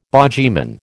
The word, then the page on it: bogyman: American English